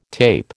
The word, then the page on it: tape: American English